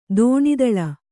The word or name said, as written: ತನುತಲ